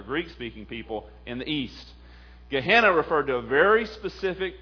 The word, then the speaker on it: Gehenna: The word Jesus used for hell, as I said before, is a word in the Aramaic pronounced Gehenna.